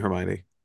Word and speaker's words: Hermione: I asked my mom, and then she told me Hermione.